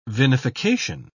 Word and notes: vinification: British English

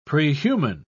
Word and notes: prehuman: British English

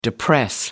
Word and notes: depress: American English

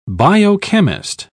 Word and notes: biochemist: British English

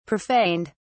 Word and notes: profaned: British English

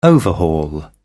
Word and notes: overhaul: British English

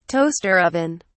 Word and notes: toaster oven: British English

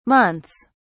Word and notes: months: British English

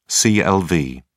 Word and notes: clv: British English

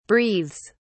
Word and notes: breathes: British English

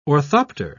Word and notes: orthopter: British English

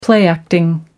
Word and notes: play acting: American English

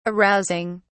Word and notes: arousing: British English

